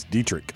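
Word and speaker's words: Deatrich: Now, how'd you say his name? – Dietrich. Am I wrong? – It's Dietrich.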